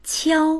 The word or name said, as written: qiao1